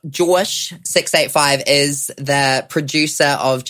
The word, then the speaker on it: JAWSH: Which I didn't know how to say his name until I was like, is it Josh 685? But it's Josh. Of course it is. – J-A-W-S-H. – He really won the heart of the nation that night.